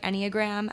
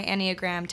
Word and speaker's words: Enneagram: I think I'm saying that right, Enneagram. And I just did my Enneagram test recently, and I'm a 7.